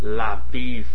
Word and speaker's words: LaBeouf: LaBeouf.